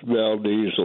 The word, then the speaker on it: Valdez: And Valdez, some pronounce it Valdez, but it's Valdez, Alaska.